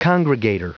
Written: congregator

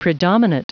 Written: predominate

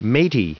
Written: matey

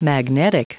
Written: magnetic

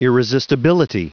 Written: irresistibility

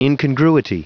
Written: incongruity

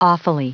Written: awfully

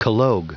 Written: collogue

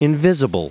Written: invisible

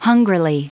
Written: hungrily

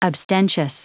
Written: abstentious

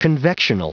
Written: convectional

